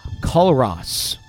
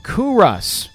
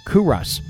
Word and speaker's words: Culross: to a beautiful hamlet called Culross. I'm sorry, I said it wrong. Culross. She got the pronunciation here. Culross.